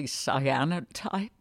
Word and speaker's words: cyanotype: Would you explain for us how your work is sourced from nature, and what a, am I saying it correctly, cyanotype? – Yes, that's perfect.